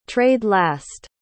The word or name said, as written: trade-last